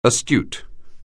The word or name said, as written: astute